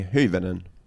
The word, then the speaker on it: Hyvönen: Så det uttalas Jenny Hyvönen. Det är det, okej. Okay.